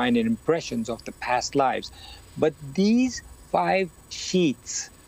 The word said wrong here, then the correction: sheaths: am I saying it right? – Sheaths, yeah. Oh, yeah, sheaths. – Sheaths, yep.